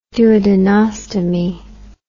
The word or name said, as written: duodenostomy